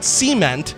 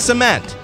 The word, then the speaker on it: cement: It's not cement, it's cement.